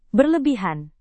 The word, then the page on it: berlebihan: Indonesian